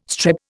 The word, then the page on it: strep: Indian